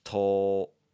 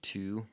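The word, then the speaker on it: To (と): If you're looking at the romaji of this, there's going to be a little bit of a desire to want to say two, but this is pronounced.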